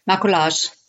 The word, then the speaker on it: maquillage: Maquillage.